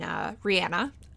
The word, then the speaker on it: Rihanna: And I think it was just last year he and Rihanna, I believe that is the proper pronunciation of her name, even though I like Rihanna better, did a live HIV test in honor of World AIDS Day.